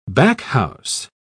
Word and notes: backhouse: British English

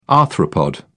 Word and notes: arthropod: British English

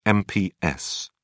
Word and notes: mps: British English

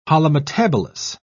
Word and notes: holometabolous: British English

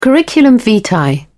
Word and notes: curriculum vitae: British English

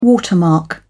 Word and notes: watermark: British English

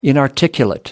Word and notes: inarticulate: American English

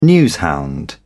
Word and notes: newshound: British English

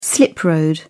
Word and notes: slip road: British English

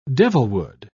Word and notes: devilwood: British English

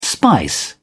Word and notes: spice: British English

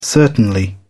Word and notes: certainly: British English

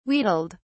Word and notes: wheedled: British English

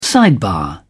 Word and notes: sidebar: British English

